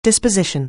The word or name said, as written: disposition